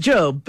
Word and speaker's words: Gob: Well, it's pronounced Job, but it's spelled Gob, so everybody introduces him as Gob.